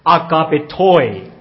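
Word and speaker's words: agapetoi (ἀγαπητοί): 这个字原来我用发这个希腊原文的发音呢，叫做 agapetoi。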